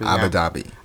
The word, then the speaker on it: Abu Dhabi: Abu Dhabi. – Yeah. – Am I saying it right? – Abu Dhabi, Abu Dhabi.